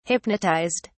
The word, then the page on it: hypnotized: British English